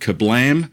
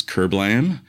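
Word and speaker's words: Kerblam!: And we are here to review the latest edition of Doctor Who, entitled Kablam, or as many people in America would pronounce, Kerblam, with the heavy R accent that we have over here, directed by Jennifer Perreau and written by Pete McTeague.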